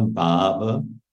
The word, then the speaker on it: Bhava: Radha Bhava Pura. So Pura here means full, and it's a long A, Bhava, Radha Bhava, over, filled with the Bhava of Radha.